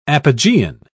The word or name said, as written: apogean